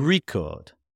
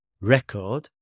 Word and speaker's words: record: For example, the word record can be a noun or a verb depending on which syllable is stressed. Record or record. Record is a noun. Record is a verb.